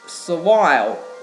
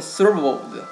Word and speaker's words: servile: 在下面呢就是 servile，也有读成 servos，它就是个奴隶的、奴性的，也是跟那个伺候人的那种感觉差不多是吧。